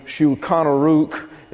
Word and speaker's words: Shulchan Aruch: Now, the Shulchan Aruch is the correct pronunciation, and we will have a lot more to say about that later on in our study.